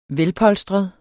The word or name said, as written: velpolstret